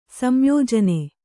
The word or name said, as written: ಸಂಯೋಜನೆ